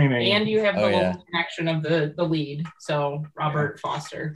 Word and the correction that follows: Forster: Forster. – That's how you say it.